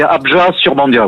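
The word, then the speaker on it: Abjat-sur-Bandiat: Alors oui, on ne prononce pas forcément le T final, c'est Abja-sur-Bandia, oui.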